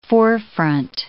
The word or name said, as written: forefront